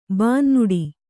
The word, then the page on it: ಬೆನ್ನೋಡು: bennōḍu